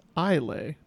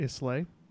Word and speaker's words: Islay: Toby and Lord John Marbury share a whiskey from the island of Islay, which is actually pronounced Islay, not Islay.